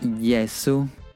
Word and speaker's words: Yesu: Ah, yo soy Yesu, que bueno, sería como la pronunciación de Jesús.